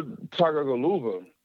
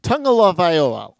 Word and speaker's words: Tagovailoa: You gotta get that first syllable is tongue. Tungaloa. And then afterwards, I have no idea. Tagovailoa, Frank Viola.